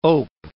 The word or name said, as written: ope